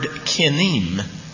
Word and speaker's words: kinnim: The Hebrew word that's translated gnat here is the word kinim. It's spelled K-I-N-N-I-M.